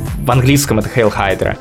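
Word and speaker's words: Hydra: Тот знаменитый кадр, в котором Капитан Америка говорит «Хейл Хайдра», кстати, правильно говорить Хайдра, а не Гидра.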